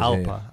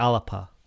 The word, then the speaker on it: Alba: Alpa. – Is there a fucking P in there? There's a real P. – It's pronounced like P. Yeah. It's like Alapa.